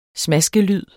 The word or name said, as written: smaskelyd